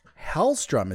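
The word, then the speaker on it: Helstrom: Hellstrom is how it's said.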